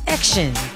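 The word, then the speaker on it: XIN: Y101, and they are called Action. All right, EX. Sorry, because their group name is called X, colon, I-N. So it's pronounced as Action.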